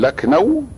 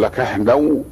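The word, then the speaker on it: لكهنو (लखनऊ): فبعض الناس يقول لكنو كما هو النطق المعتاد، وبعضهم يقول لكهنو ولا يخرجها إلا واحد متمرن على إخراج هذه الهاء.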